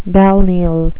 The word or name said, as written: balneal